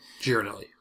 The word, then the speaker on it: Ghirardelli: Ghirardelli. – It's Ghirardelli. – Is it Ghirardelli? – Yeah.